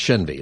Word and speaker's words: Shenvey: I think I mispronounced his name like half the time, but it's Shenvey is what it is, Neil Shenvey.